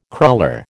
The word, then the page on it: cruller: American English